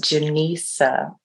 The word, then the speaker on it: Jenisa: My name is pronounced Jenisa. – Oh, Jenisa. I'm sorry. – It's like Jen and Lisa.